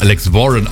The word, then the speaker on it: Alex Warren: Alex Warren, wie die Knarren. Das ist nicht so schön, ne? Heißt ja auch Alex Warren auf Englisch ausgesprochen.